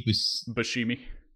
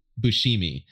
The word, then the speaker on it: Buscemi: Steve Buscemi. – Buscemi.